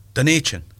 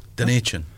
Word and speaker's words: Dunedin: Dunedin. Yeah. – Dunedin.